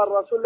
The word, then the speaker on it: الرسول: هذا المسجد ولا تنطق اللام ال، وأما الرسول لا تنطق اللام.